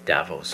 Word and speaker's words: Davos: You know, and even Greta was featured with Jane and Goodall at Davos, and am I pronouncing it right? Davos. – I think it's Davos.